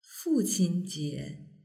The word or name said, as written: fuqinjie